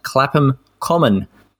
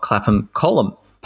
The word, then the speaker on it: Clapham Common: I'm not sure I'm a reliable backstop when it comes to pronunciation, so apologies to the good people at Clapham Common Parkrun, not Clapham Column Parkrun.